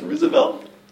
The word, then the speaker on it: Roosevelt: Is it pronounced Roosevelt? – Yes.